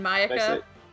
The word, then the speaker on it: Mayaka: You mean Mayaka? You said Miyaka or something. – Mayaka. I dropped a syllable because it's late at night.